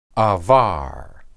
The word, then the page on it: abar: Hebrew